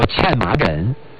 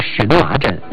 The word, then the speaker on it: 荨麻疹: 嗯，这最常见到的一个皮肤病，把它叫欠麻疹。今天大家也把它读成荨麻疹。